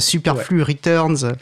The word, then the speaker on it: Superflu Riteurnz: la première question, et je pense que je suis pas le seul à me la poser, c'est euh, comment est-ce que ça se prononce Est-ce qu'il faut probablement prendre son, son, bon gros accent français, Superflu Returns C'est exactement ça.